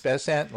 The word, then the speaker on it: Bessent: Do you pronounce it Bessent like it rhymes with pissant? – That's not a bad way of doing it or thinking about it.